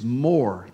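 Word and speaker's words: more (מֹר): As a matter of fact, in Hebrew, the word myrrh is more, pronounced more.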